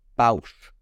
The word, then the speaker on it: Boushh: Uh, and, well, see, and recently Boish. I got. I recently built. I think that's how you the, pronounce it. – Boushh.